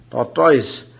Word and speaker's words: tortoise: Like the tortoise. Do they call it tortoise? Not tortoise? Oh, tortoise. They call it tortoise. How do you say? – Tortoise. – Tortoise, yeah. Okay. So tortoise, O is silent.